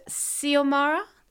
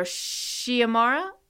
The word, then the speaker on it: Xiomara: It could be pronounced Xiomara, or Xiomara, or Xiomara. And I think it's beautiful.